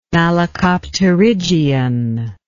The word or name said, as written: malacopterygian